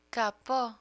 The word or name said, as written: capo